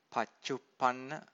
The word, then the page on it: paccuppanna: Pali